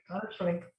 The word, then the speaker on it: Donna Schwenk: Donna Scwenk.